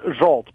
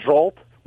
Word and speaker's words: Zsolt: It's pronounced Schalt. Remember Zsa? – Yes.